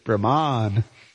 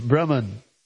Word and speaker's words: Brahman: Or if you're in ISKCON, you say Brahman. Sorry. But there's no long A there. Just to let everybody know. It's Brahman.